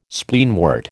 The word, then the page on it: Spleenwort: American English